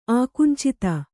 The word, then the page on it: ಆಕುಂಚಿತ: ākuñcita